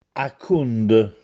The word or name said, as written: Accùnde